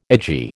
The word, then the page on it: edgy: American English